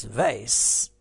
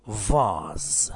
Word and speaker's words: vase: American English, it's vase, and the British say vase. So two pronunciations, vase, American, and vase, British.